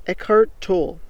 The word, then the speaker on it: Eckhart Tolle: Alright, so the guy's name is Eckhart Tolle. That's spelled E-C-K-H-A-R-T T-O-L-L-E.